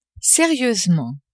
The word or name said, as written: serieusement